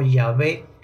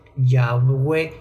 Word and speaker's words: Yahvé: Ahora hay una traducción entonces al agregarle las vocales a Adonai y se puede pronunciar como Yahvé, Yahweh, Yahvé.